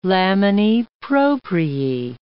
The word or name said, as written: laminae propriae